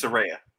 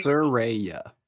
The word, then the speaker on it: Saraya: Which way is it? Huh? It's Saraya, right? – Yes. – Okay. Fuck, it's in my head now. – Saraya. – She said they need to ship Saraya off.